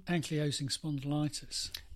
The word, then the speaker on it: ankylosing spondylitis: Ankylosing spondylitis.